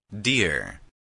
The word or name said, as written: dear